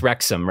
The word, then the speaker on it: Wrexham: Pronounced Wrexham, right? – Yeah, Wrexham.